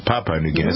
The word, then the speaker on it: Papua New Guinea: Papua New Guinea, that's how you pronounce it.